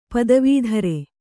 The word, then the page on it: ಪದವೀಧರ: padavī dhara